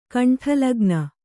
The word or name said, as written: ಕಂಠಲಗ್ನ